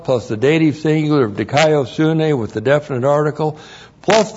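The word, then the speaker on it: dikaiosunē: Looks like that in the Greek, D-I-K-A-I-O-S-U-N, long E, dikaiosune.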